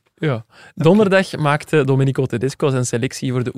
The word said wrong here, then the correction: Domenico: Is het niet Domenico? – Wat zei je? – Domenico. – Domenico. – Ah ja, mijn uitspraak is soms nog...